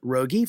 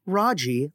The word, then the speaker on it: Raji: Also, you may know me as Rogi from other conversations, but in fact, I'm pronounced Raji, like Roger, but cuter.